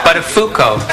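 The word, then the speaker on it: Buttafuoco: No, it isn't Matthew. It's, But a Foucault. But a Foucault. What did I say? Well, Matthew, of all the possible mispronunciations of that name, you seem to have stumbled upon absolutely the worst one.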